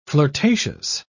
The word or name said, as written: flirtatious